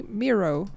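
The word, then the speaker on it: Miró: and then they were like, "Yeah, like the artist Mi- Miro."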